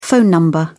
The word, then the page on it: phone number: British English